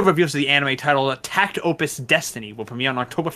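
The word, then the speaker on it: Takt Op.: Tact OP, pronounced Tact Opus, as well as Tact Opus project, which depicts music cart girls who battle with classical music.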